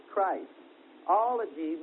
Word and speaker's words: Christology: The word Christology means the doctrine about Jesus Christ. Ology means the study of or the doctrine of Christ. And it's pronounced Christology with a short I, like Christmas or christening.